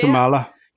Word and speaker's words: Kamala: Kamala. – Yeah, Kamala. We've all got accents, so we can all blame it on that anyway. – Yeah, because I heard someone on the news being like, Kamala, and I was like, no, that's not the way I would say that.